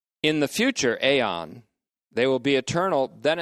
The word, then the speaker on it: aion: That is, their life in the future aeon, A-I-O-N, long O, which will be eternal.